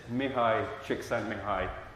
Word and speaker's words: Mihai Csikszentmihalyi: I can pronounce this. The correct pronunciation of his name is Mihai Csikszentmihalyi.